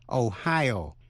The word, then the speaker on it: Ohio: Tiểu bang Ohio nhấn mạnh vần nhì, nhưng tiểu bang Idaho nhấn mạnh vần đầu.